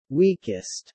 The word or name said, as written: weakest